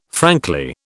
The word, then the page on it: frankly: British English